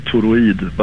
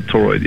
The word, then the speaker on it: Toroid: Well, we, in Sweden we say toroid, but it's kind of toroid, yeah. as Centaurid in English, yeah.